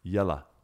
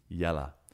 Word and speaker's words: Yella: Pronunciation Yell minus OW plus the U from Butterbur. Yella. Yella.